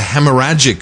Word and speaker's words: hemorrhagic: But it's this is the hemorrhagic flu.